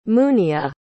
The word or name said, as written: muniya